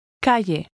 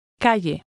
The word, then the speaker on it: Calle: Pay attention to the sound of the double L, which is usually pronounced like an English Y. – Calle. Calle.